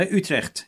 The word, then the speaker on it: Utrecht: Yeah, say it properly because I can't say it properly. – No, Utrecht. Yeah, Utrecht. – I see, Utrecht.